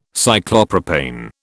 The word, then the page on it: Cyclopropane: British English